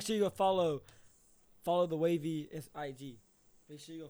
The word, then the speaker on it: Wavey: So the Wavey podcast, it is the IG is official Wavey Productions _. Now that's Wavey with the E. Okay. – It's a silent E, but it's an E. – Yeah. It's an E in there and then a Y.